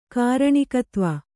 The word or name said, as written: ಕಾರಣಿಕತ್ವ